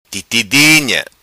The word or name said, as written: Titi Dinha